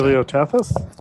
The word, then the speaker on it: Paleotethys: It was preceded by the Paleolithic Ocean. Ethys. Paleolithic. I can't pronounce it. – Paleotethys. – Yes, it's a Paleotethys.